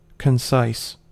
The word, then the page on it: concise: American English